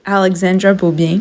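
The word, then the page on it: Alexandra Beaubien: English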